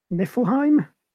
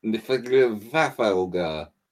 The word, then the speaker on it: Niflheim: Basically, someone is amassing the armada of, and I can never pronounce this, Niflheim? – No, it's definitely Niflheim.